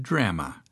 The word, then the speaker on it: drama: you have added drama, pronounced, of course, with a flat A as in grandma.